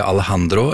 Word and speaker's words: Alejandro: Altså, til og med Lady Gaga, som kanskje skulle tro drar på seg en liksom amerikansk uttale av Alejandro, har denne tydelige lyden som låten da bærer preg av. – Jod blir jo H på spansk. Alejandro, et slags frampeik til hva som skjer i timen her i dag.